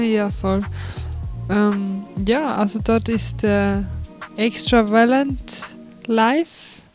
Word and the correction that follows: Extrawelt: Extrawelt. – Extrawelt, genau. Sorry, ich habe sie dann mit einem Slang ausgesprochen.